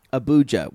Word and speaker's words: Abuja: Abuja with a Abuja. – Hard J. – Abuja. – There you go. – All right. They're going to Abuja. – Not bougie.